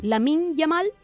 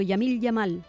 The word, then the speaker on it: Lamine Yamal: Efectivamente, y al pobre Lamine Yamal, siempre lo digo mal, lo digo al revés, se parten de la risa en mi casa. Yo les digo, Yamil Yamal, se parten.